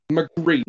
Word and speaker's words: Magritte: Magritte. Am I saying that? – Magritte.